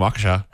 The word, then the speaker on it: Waukesha: Yeah, from Waukesha.